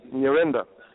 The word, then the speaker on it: Nyirenda: Nyirenda, NYIRENDA.